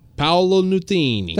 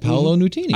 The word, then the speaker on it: Paolo Nutini: What is it really? – Paolo Nutini. – That's what I said, Paolo Nutini.